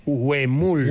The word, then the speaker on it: huemul: Creo que se pronuncia Emul. – Huemul.